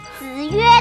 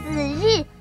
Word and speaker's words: 子曰: 你少臭盖了，子曰都念成子日，还有学问呢。